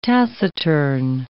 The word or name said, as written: taciturn